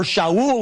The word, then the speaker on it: Shaul: It's just that he had a Hebrew name, Saul, or Shaul, we would pronounce it in Hebrew, and he had a Greek name, Paul.